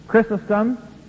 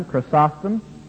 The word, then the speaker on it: Chrysostom: Chrysostom, or as some pronounce him, Chrysostom, 345 to 407, Theodore, 350 to 428, Eusebius of Caesarea, I have him out of chronological order here, 260 to 340.